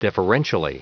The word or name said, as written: deferentially